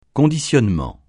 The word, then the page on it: conditionnement: francais